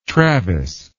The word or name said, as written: travis